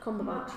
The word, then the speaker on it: Cumberbatch: Obviously, his history, the Cumberbatch family. Am I pronouncing that right? – Cumberbatch.